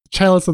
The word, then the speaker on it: chalice: I was sure it was Calis. Like I was hundred percent sure about what I was doing. Chalice of the Void.